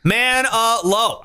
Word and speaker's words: Manilow: And then Manilow. Fuck yeah. I mean, if you don't know that that's three syllables just by listening to it, it's man uh low.